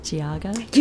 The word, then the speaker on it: Geauga: Did I say it right, Giaga? – You did, Yay. you did.